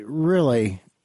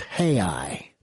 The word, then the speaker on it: real: Like the word for dollar down there is R-E-A-L. Well, I would pronounce that real. It's not real. It's hei.